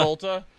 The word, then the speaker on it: Volta: That's how you say it. Huh. Yeah, Volta.